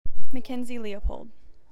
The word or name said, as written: Mackenzie Leopold